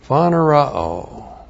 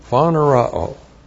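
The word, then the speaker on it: Phanerao (φανερόω): Phanerao. P-H-A-N-E-R-O, long O. Phanerao.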